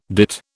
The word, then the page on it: dit: British English